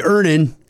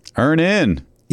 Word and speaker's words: Earnin: I'm going to pronounce this properly, Earnin. – Earnin. – E-A-R-N-I-N is pronounced Earnin. I don't know how else you would pronounce it, but they really want it clear that that's how to pronounce it.